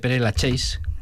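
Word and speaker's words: Père-Lachaise: Y en París tenemos el cementerio de Père Lachaise.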